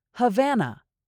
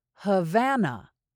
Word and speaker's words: Havana: Havana. This word is spelled with a lot of A's, but the middle A is different. Havana. Havana. Havana.